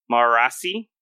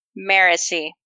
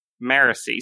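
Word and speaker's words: Marasi: Marassi? – Nailed it. – Nailed it incorrectly? – Right, yes. Nailed it like IKEA furniture that you have incorrectly assembled. – How do you say her name? – Marassi. – Marassi. So the stress is on a different syllable.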